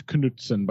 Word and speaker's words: Knutson: Pretty sure it's Knutson, by the way. – Knutson? – Only English has, of all the Germanic languages, only we have the silent K. – That's true. – Knutson. It's King Knut, not King Knut.